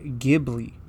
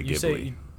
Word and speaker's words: Ghibli: I say Ghibli. – I also say Ghibli. – See, I thought for the longest time it was Studio Ghibli, but...